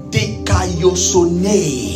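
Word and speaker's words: Dikaiosune (δικαιοσύνη): Let me help you pronounce it. Say dik. Say ah. – Ah. – Say yos. – Yos. – Say o. – O. – Say ne. – Ne. – So dik ayosune. Dikaiosune.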